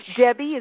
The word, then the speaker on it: Debbi: My website is my name, which is spelled very differently than it sounds. Debbie is D-E-B-B-I, there's no E, and my last name's Austrian, so it's D-A-C-H-I-N-G-E-R. It's D-E-B-B-I-D-A-C-H-I-N-G-E-R.